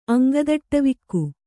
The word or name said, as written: ಅಂಗದಟ್ಟವಿಕ್ಕು